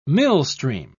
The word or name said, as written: millstream